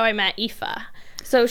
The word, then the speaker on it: Aoife: And her name is spelled really awesomely A-O-P. Trippy. Yeah. So it's like, yeah. – But A-O-I-F-E, you say Ifa. – But it's Ifa.